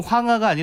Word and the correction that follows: 항아: 아니라 항아. – 항아죠. 항아. 근데 이제 발음상 예, 예, 예. 이제 그렇게 황하로 발음을 했는데 월궁에 있는 항아.